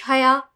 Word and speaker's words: Hayat: Mehvish Haya plays Aisha. "My friend told me T is silent in Hayat."